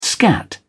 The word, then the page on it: scat: British English